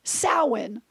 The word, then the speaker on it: Samhain: I always thought it was Samhain. Yeah. It's pronounced Samhain.